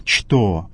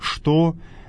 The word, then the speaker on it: что: Говорят, как по-русски «что», но в церковнославянском языке это слово надо произносить так, как оно написано. «Что», а не «что».